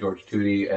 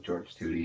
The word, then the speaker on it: George Tutie: G-E-O-R-G, and then my last name is spelled T-U-T-I-E. Controversy at growing up in high school, they would call me Tuttle, Tutsy.